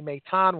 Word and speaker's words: Maton: We weren't even sure how to say his name. Was it Mation? It turned out to be Maton.